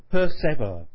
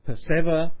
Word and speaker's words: persever: Then while we live in love, let's so persever that when we live no more, we may live ever." Persever is the old pronunciation of persevere, which in the old days rhymed with ever. Persever, ever.